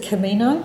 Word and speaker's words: Camino: Is it Camino? – I don't know. Sounds sort of Spanish, doesn't it? – Yes, that's pretty right.